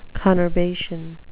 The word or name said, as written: conurbation